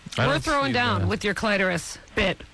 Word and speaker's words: clitoris: Clitoris is the first, but here's the thing that Kathy might be interested in, because she mentioned it earlier. – And I have one. – The secondary pronunciation is clitoris. – Get out of here. You're making that up. – That's because the English word comes from a Greek word originally, clitoris, K-L-E-I-T-O.